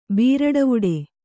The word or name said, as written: ಬೀರಡವುಡೆ